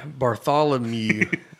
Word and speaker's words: Bartholomew: Bartholomew. I don't. Is that I say that right? Yeah. Oh, I you got it. You nailed it right there.